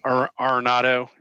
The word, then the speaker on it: Arenado: I really considered Arenado. Is that how you pronounce it? For the Cards. – Yep.